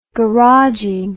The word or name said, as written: garaging